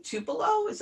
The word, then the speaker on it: Tupelo: So the movie was shot in Tupelo. Am I saying that correctly? – You are.